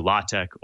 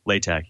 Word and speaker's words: LaTeX: So LaTeX, it's pronounced either LaTeX or LaTeX, even though it's spelled latex, it's kind of a good way to describe it is a document processing system.